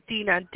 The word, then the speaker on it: Dina: Hi, my name is Dina.